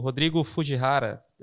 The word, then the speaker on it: Rodrigo Fujihara: E hoje trouxemos aqui o, o Fred, meu sócio, como convidado, e, e o Rodrigo, o Rodrigo Fujihara.